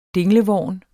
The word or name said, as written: dinglevorn